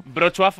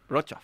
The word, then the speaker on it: Wrocław: Buenísima pronunciación del polaco, ¿eh? – A ver, otra vez. Wrocław. – Es que la gente dice Wrocław. – Bueno, yo hubiera dicho Wrocław directamente. Vamos.